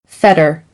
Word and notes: fetter: American English; ɾ - t

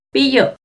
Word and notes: piyo: Náhuatl

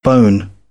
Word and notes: bone: British English; əʊ

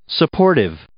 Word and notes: supportive: adjective

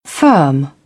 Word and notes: firm: British English; ɜː

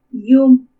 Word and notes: Iumu: Purépecha

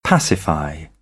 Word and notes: pacify: British English; ɪ

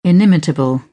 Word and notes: inimitable: British English

